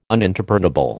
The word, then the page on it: uninterpretable: American English